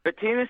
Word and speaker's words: Betinis: Am I butchering your pronunciation of your last name, or is that Betinis? Is that right? – Betinis is correct.